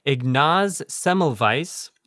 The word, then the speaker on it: Semmelweis: We'll be talking about a guy named Ignaz Semmelweis, and I hope I'm pronouncing that right.